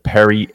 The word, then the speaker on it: Perri: If you want to give me a follow, it is Justin Perri 8. Last name is spelled P-E-R-R-I and the number 8 on Twitter.